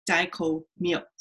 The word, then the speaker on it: Dai Ko Myo: So anyway, for what it's worth, Dai Ko Myo is pronounced Dai Ko Myo. So really fast, Myo. I've heard Dai Ko Myo, Dai Ko Myo, it's Dai Ko Myo.